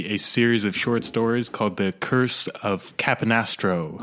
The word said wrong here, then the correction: Capistrano: I think it's Capistrano. – Capistrano. – The Curse of Capistrano.